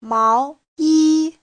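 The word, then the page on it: 毛衣: mao2yi1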